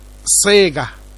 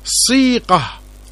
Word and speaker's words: صيقة: أقول للمستمع الكريم، هذه الكلمة ينطقها العامة صيغة، وهذا تحريف لنطقها الفصيح صيقة بياء صحيحة وليس بياء ممالة.